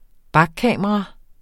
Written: bakkamera

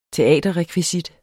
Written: teaterrekvisit

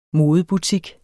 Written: modebutik

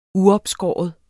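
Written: uopskåret

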